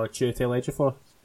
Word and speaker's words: Chiwetel Ejiofor: Oh, Chiwetel Ejiofor.